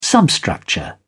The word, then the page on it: substructure: British English